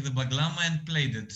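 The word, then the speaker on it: bağlama: How do you pronounce it? A baklama? – Baglama, yeah.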